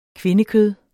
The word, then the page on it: kvindekød: [ ˈkvenəˌkøð ]